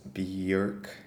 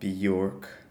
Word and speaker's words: Björk: Bjork was born in 1965 in Reykjavik, and her full name is actually Björk Guðmundsdóttir, and it's pronounced Björk, not Bjork.